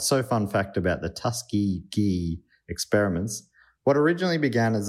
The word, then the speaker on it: Tuskegee: This is something that we mispronounced once, and a lot of people corrected us. And I'm like, Tuskegee.